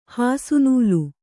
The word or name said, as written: ಹಾಸುನೂಲು